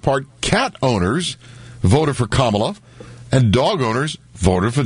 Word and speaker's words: Kamala: Not Kamala, but Kamala. Doesn't really matter. – Is it Kamala or Kamala? – It's Kamala. – Kamala. – Kamala. – Mm-hmm. – That's the correct pronunciation.